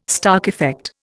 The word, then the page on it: Stark effect: Indian